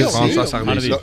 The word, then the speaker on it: François Hardy: Bueno, el martes pasado falleció François Hardy, ¿se dice así? – Sí, François Hardy.